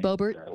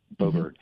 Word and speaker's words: Boebert: Boebert. – Boebert.